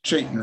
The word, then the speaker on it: Chaitin: I mean, he does insist that it be pronounced Chaitin rather than Chaitin.